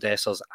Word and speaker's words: Dessers: It's taken me about 4 days to get his name pronounced right in the news show. I did change it up every day that I said it, but Cyril Dessers and Danilo from Feyenoord, they have been the two main rumours we're hearing.